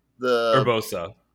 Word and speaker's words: Urbosa: So, also honorable mention, is it Orbusa? Is that the one from the desert? Urbosa, yeah.